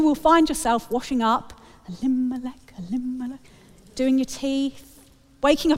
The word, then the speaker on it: Elimelech: So I think it's because one of the characters has a four-syllable name and we place the emphasis on the second syllable. And what that means is every time I hear the name Elimelech, in my head I'm singing that to the tune of A Whim Away.